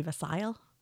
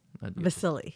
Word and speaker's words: Vassil: I thought there might have been an E at the end. – That would be Vassil? – No. – Vassili. – Yes. – Okay. – Vassil. Yo, what's up, Vassil? – Okay.